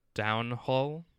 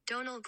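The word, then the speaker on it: Domhnall: Their pronunciation looks like downhole. – Downhole? – I'll play it. – Oh. – Donald Gleason.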